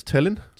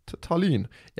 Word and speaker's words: Tallinn: Ja, det udtales Tallinn. – Tallinn, ja.